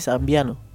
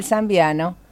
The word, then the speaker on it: zambiano: Es Zambiano. – De Zambia, sí. – Ah, ¿sí? Googleelo por las dudas y de paso aprendemos. – Si yo lo busqué y se dice Zambiano.